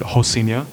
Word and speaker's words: Rocinha: The Brazilians, they pronounce the R as an H. So it's actually worded out as Rocinha, but they pronounce it Rocinha.